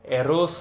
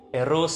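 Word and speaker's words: Rrose: The great French conceptual artist signed some of his works with the pseudonym Eros c'est la vie, and Eros is spelled R-R-R-O-S-E, as in the name Rose, but with the extra R's in it so that it was pronounced in French, one would roll the R's.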